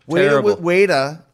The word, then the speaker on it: Ueda: My first match in FMW was against Weta. – Terrible. – Weda, which is spelled UEDA, was a kickboxer who had zero wrestling experience, didn't want wrestling experience.